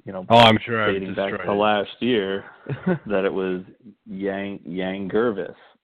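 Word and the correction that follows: Yangervis: But the G is silent, so it's Jan Hervis Solarte.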